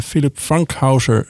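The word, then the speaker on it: Philipp Fankhauser: Philip Frankhauser. Dat is een beetje op zijn Engels uitgesproken. Maar eigenlijk komt die man uit Zwitserland. Philip Frankhauser, zou je dan wel zeggen. Hij heeft verschillende albums gemaakt.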